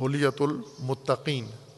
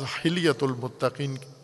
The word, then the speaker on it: حلیۃ المتقین: اس کو دو طرح سے تلفظ کرتے ہیں کتاب، دونوں تلفظ ٹھیک ہیں، معنی صحیح بنتا ہے، لیکن ہے میرے خیال میں وہ درست حلیۃ المتقین، بعض حلیۃ المتقین کہتے ہیں اسے۔